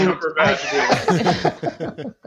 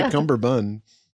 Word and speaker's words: Cumberbatch: That Cumberbatch. Well, you know, Martin Freeman and that other fucking guy. Whoever that other guy. – Yeah, she didn't say Cumberbun. – Yeah, I said it right, right? – Yeah.